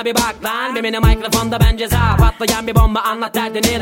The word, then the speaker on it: Ceza: En realidad se pronuncia Ceza.